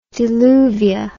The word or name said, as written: diluvia